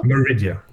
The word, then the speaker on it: Maridia: Eh, Maridia. Uttalar jag det rätt nu? Ja. – Maridia. Maridia på engelska.